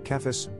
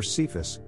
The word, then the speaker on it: Cephas: In Aramaic there is only one word for rock and that is kepha, which in Greek is pronounced k-edify sigma, kephas, or cephas, hard C.